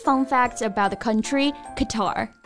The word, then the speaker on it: Qatar: Qatar 中音呢在第二个音节。